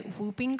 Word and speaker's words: whooping: I had, I don't know, okay, for an American, you might say whooping cough, but apparently there's a silent W in Australia.